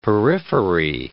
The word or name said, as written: periphery